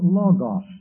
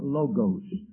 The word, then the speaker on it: logos: There's a word, it's pronounced logos. You might hear some people call it logos. L-O-G-O-S, a Greek word, logos, which means the word.